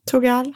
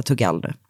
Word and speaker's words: Thugal: Säger vi att Tugal. – Han uttalar det. T-H-U-G-A-L. Han kommer att heta Tugal nu.